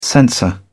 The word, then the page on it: censor: British English